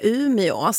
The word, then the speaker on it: Umeå: Tycker du jag var hård förut, Evelina, när jag sa att det heter Umeå, inte Ume? – Nej, men jag tycker det är bra. Det är ju vissa som har den åsikten att vi i radio ska uttala åen också.